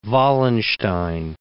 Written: Wallenstein

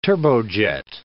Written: turbojet

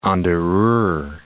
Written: Mulheim an der Ruhr

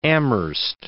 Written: Amherst

